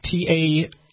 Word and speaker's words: Taibbi: His handle is at TAIBBI.